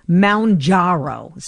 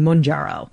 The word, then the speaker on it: Mounjaro: Now it's pronounced Mounjaro. So the Jaro is the X is where you put the accent, but they're saying it's more Moun, M-O-W-N, Mounjaro. And I've been saying Munjaro.